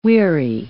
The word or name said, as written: weary